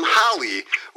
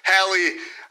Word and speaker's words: Hallie: If their name is Hallie, you do not call them Holly, which I unfortunately did quite a few times to the guest that we have right now, Hallie McGuire. Hallie, are you happy that I'm saying your name correctly? – It's awesome.